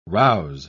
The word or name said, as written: rouse